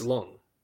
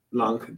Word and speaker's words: Leung: Sorry, Tony Leung, right? – I think it's Long. – Long, thank you.